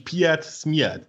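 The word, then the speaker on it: PietSmiet: Dann guckt ihr mal das Verarschungsvideo da drauf zu Pete's Meat an, wie ihr Name ausgesprochen wird. Das ist nämlich Piet Smiert.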